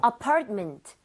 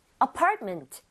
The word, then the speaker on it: apartment: So 강세를 제대로 주면 MENT가 그렇게 발음이 돼요. 여기 강세 주고 한번 해 보면 네. – apartment보다는 apartment. apartment. 그래요? – apartment. apartment.